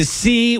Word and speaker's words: SIE: which is a Tuesday night at the C, which is spelled S-I-E, Film Center in Denver.